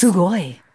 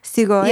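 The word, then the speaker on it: Sugoi (すごい): Sugoi. Cool. Awesome. Sugoi. – Wait, what? How do you say it? Sugoi? – Yeah, S-U-G-O-I. S-U-G-O-I.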